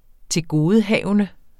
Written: tilgodehavende

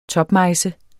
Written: TOPMEJSE

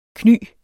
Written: kny

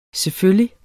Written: selvfølgelig

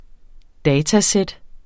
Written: datasæt

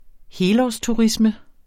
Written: helårsturisme

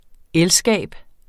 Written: elskab

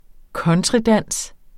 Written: countrydans

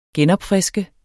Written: genopfriske

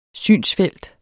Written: synsfelt

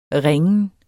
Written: ringen

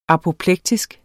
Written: apoplektisk

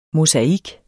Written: mosaik

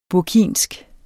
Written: burkinsk

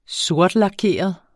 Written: sortlakeret

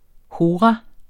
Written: hora

